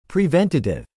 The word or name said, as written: preventative